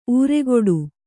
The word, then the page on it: ಊರೆಗೊಡು: ūregoḍu